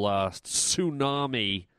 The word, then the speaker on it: tsunami: I mean, you know, I'd probably be a bit insensitive if I didn't talk about the whole tsunami with the silent T in it or whatever.